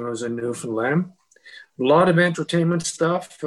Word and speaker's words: Newfoundland: And by the way, for anyone who wants to understand how to say that province, say in your head, understand Newfoundland. That's how you say it. So it's not Newfoundland. It's not Newfoundland, as some of our American friends say.